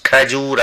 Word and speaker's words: کجور: کجور، ملاحظه بفرمایید تلفظ کجور است، خرما را میگویند.